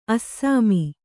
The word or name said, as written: ಅಸ್ಸಾಮಿ